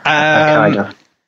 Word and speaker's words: Hokkaido: Garvu Gill guided his team MRF Skoda Fabia R5 to his fourth consecutive Asia Pacific Rally Championship success on last weekend's Rally Hokkaido in Japan. Do you see how I pronounced that? – Hokkaido. Yeah.